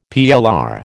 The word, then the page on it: PLR: American English